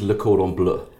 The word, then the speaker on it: Le Cordon Bleu: What is the pronunciation? – It is Le Cordon Bleu. So it's French for blue. It's the blue ribbon.